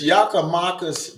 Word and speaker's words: Giakoumakis: So, Is Giakoumakis, did I say it right? – Yeah.